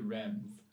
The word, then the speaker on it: Reims: To Reims. – Okay. For coronation. So she was supposed to bring Charles VII to Reims for coronation.